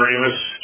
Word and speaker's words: Remus: Uh, John Remus, Chief of the Missouri River Basin Water Management Division. My last name is spelled R-E-M-U-S.